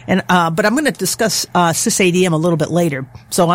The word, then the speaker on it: SysADM: One of the major feature additions of TrueOS is a tool called SysADM, and that's how they pronounce it.